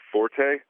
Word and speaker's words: forte: So he says that the people commonly mispronounce the word forte when referring to a strength. He says when referring to the musical term for loudness, that's fine because it comes from the Italian root for loud. But when referring to strength, it comes from the French root. And so he says you're supposed to pronounce it fort instead of forte.